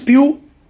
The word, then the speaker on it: Pugh: Y bueno, Florence Pugh, que me cuesta de pronunciarla así, porque yo no lo pronunciaba así, pero es así, Pugh.